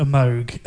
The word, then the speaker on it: Moog: I think she was one of the first sort of pioneers to really bring the Moog synthesizer, because that's how you say it, Moog, not Moog.